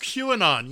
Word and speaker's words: QAnon: Is that how it's pronounced? – QAnon, yeah.